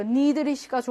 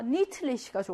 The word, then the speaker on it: endlich: 예외로 그냥 얘는 드로난다. 그래서 니드리시입니다. 실제로 여러분 발음해 보세요. 니드리시가 좋아? 니틀리시가 좋아? 니틀리시 발음이 좀 어렵잖아. 니드리시. 결국은 발음이 편한 쪽으로 이게 바뀌는 게 예외거든요. 편하게 바뀌어서 니드리시입니다.